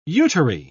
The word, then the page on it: uteri: British English